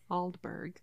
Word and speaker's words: Aldeburgh: Aldeburgh.